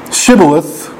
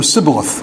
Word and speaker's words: shibboleth: You remember the battles in the book of Judges where the sign of whether someone was Benjaminite, I think it was whether they could say Shibboleth or Sibboleth. I mean, it's basically the same word, but they pronounce it differently.